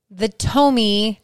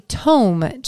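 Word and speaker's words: Tomme: We were both wrong. It's actually Tome cheese is how they pronounce it. I talked to Brenda Hastings from Hastings Dairy, Browdy Cow Creamery. And she's like, it's Tome cheese.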